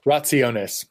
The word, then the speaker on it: rationes: Is it rationes? – Rationes. – Rationes. Okay.